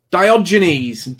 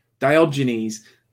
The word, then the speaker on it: Diogenes: It sounds like this. Diogenes. That's it. Diogenes.